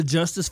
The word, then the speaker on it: Justice: Did I tell you about the Justice family back where I'm from? – Nuh-uh. – Okay, so there's Just Ice, like spell it Justice, J-U-S-T-I-C-E, Justice, J-U-S-T-I-C-E. And then there's the Just Us, J-U-S-T-U-S.